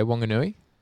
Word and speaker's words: Whanganui: Do you reckon I pronounce that correctly? Have you ever been to Whanganui? – Yeah, Whanganui. – Whanganui, got that one.